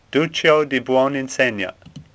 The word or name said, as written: Duccio